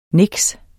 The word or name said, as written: niks